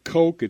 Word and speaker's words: Koch: Now, it's pronounced Koch. It's spelled Koch.